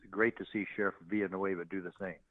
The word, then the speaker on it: Villanueva: Villanueva.